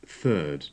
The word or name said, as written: third